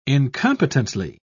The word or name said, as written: incompetently